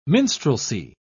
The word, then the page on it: minstrelsy: British English